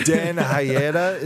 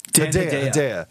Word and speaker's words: Dan Hedaya: Dan Hayeda, is that how you say his name? – Dan Hedaya.